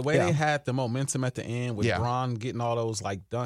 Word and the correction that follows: Braun: I think it's pronounced Brown, but it's spelled Braun.